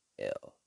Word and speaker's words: Brasel: I'm trying to figure out which one we're going to play, though, because, I think I'm going to play Shine by Ty Brazel. Brasel. Spell it again. Brasel. Ty Brazel. Shine.